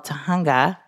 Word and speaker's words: Tujunga: Teresa grew up in a suburb of Los Angeles called Tujunga.